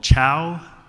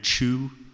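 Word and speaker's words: Chou: My last name is spelled C-H-O-U. I have been called Chow and Chu my whole life. My dad decided to say Chu when I was between 16 and 18, and then he switched back. I have an uncle that has our last name spelled C-H-O-W, another that's spelled T-C-H-O-U, and one that's a Ming.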